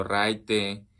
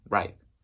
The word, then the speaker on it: raid: Que sí, es un anglicismo de ride. Pero aquí lo pueden escuchar como ride, medio ride. Medio raite, pero bueno, se escribe R-A-I-D, ride.